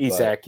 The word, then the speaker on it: Isak: Another thing I want to mention, Isak or Isaac. I'm not sure exactly how you pronounce it. – Isak. But, yeah.